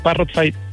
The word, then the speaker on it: Parrotfy: Exactamente, Parrotfy, tal cual como lo dijiste, Parrot, como el loro, con F-Y al final.